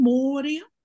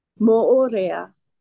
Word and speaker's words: Moorea: Moria? – Moorea.